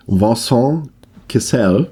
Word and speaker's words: Vincent Cassel: Vincent Cassel, ich habe es jetzt nochmal geübt, er ist nicht hier amerikanische Aussprache, ist ja ein Franzose.